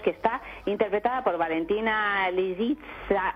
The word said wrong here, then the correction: Lisitsa: No, lo has dicho, lo has dicho fatal. – A ver cómo es. – Pues hombre, Lisitsa. – ¿Lisitsa? – Lisitsa. Lisitsa, con S.